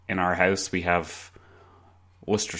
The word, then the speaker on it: Worcester: I'd just like to clarify, I know that it's pronounced Worcester,